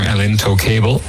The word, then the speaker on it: El Intocable: El Intoxicable, or El Intocable, perhaps to be a better pronunciation.